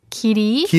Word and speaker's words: 길이: It's 길 and 이, but it's, you can pronounce it as 길이.